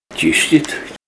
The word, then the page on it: chischit: Ninilchik Russian